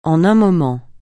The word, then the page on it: en un moment: français